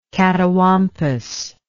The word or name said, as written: catawampus